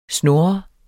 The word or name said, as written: snur